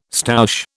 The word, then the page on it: stoush: British English